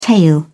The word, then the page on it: tail: British English